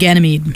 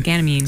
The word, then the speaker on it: Ganymede: Autumn and Ganymede. – Ganymede.